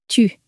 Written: tu

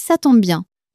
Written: ca tombe bien

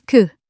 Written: que

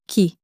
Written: qui

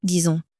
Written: disons

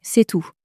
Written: c est tout

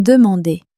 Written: demander

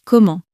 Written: comment

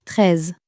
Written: treize

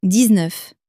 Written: dix neuf

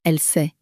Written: elle sait

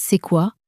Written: c est quoi

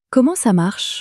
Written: comment ca marche

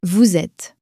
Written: vous etes

